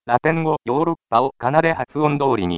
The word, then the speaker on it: ヨーロッパ: こうして、ヨーロッパという発音が定着したのですが、後になって、今度は表記を発音に合わせて、エウロパではなく、ヨーロッパと書くようになりました。